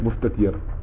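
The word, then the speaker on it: مستتر: نعم لا يا فتو C'est deux têtes, Moustatir. Moustatir.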